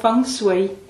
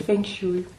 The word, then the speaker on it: Feng Shui: Ja, hej, jeg har arbejdet med en kinesisk livsfilosofi, som hedder Feng Shui, men mange herhjemme har som vane, eller jeg skal nok rette at sige uvane at udtale det Feng Shui.